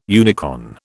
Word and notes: unicorn: British English

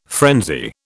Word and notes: frenzy: British English

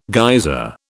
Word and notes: geyser: British English

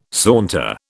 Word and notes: saunter: British English

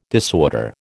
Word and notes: disorder: American English

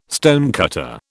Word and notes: stonecutter: British English

